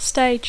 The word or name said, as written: stage